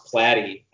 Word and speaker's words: Platty: It's actually pronounced Platty, and I started using my middle name to try to lead people to that. Like, there's a rhyming scheme to it, like Kent Lee Platty. And it ended up totally screwing that up. And people who were pronouncing my name started pronouncing it wrong. They started calling me Kent LaPlatte. I was like, how did I become French?